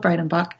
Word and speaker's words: Breidenbach: And I look forward to having you with us next time here on Lit Up with Angela Breidenbach. My name is spelled A-N-G-E-L-A. My last name is B-R-E-I-D-E-N-B-A-C-H.